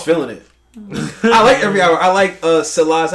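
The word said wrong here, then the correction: Selah: How you pronounce it? – Salah. – I mean, Follow God. Salah.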